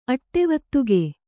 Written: ಅಟ್ಟೆವತ್ತುಗೆ

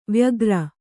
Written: ವೈಯಾಘ್ರ

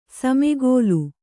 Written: ಸಮಕಲು

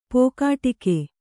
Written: ಪೋಕಾಟಿಕೆ

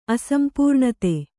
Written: ಅಸಂಪೂರ್ಣತೆ

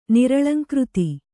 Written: ನಿರಳಂಕೃತಿ

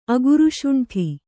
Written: ಅಗುರುಶುಂಠಿ

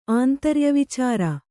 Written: ಆಂತರ್ಯವಿಚಾರ

